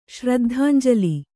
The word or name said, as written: ಶ್ರದ್ಧಾಂಜಲಿ